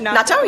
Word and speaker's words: natto: So let's start with natto. – Uh-huh. – Is that how you pronounce it? – Natto, yeah.